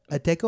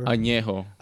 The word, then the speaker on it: Añejo: The tequila Ateco or? – Añejo.